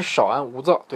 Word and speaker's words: 少安勿躁: 这个其实是少安勿躁，对，这个字念少安勿躁啊。这个小课堂教你一个成语的发音。